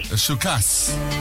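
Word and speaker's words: Puskás Aréna: Shukas. – That's how you pronounce it, okay. – Yeah, Shukas Arena, all right? – Okay.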